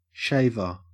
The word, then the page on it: shaver: Australian English